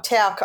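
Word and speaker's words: Towke: There was accusations that Scott Morrison had tried to use Michael Twoke's ethnic background against him. – Yeah, so Mike Tauk, I think his surname is pronounced.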